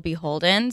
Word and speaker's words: BHLDN: So Beholden, it's spelled B-H-L-D-N, but it's called Beholden.